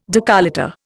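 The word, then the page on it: décolleté: Indian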